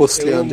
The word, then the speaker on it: Ostrander: John Ostrander, acho que é assim que se pronuncia. É Ostrander.